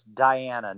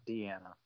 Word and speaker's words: Diana: Just to let you know, it's Diana, not Deanna. – Fair enough. All right, so... you're absolutely right, because my sister-in-law is Deanna, and it's spelled D-E instead of D-I, so I'm so used to saying Deanna that I screwed that one up.